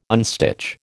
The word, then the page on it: unstitch: American English